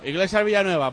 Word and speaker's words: Iglesias Villanueva: Iglesias Villanueva, por todas las veces que lo he dicho, lo digo bien ahora, ya está.